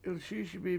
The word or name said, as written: 7ilSIISibi7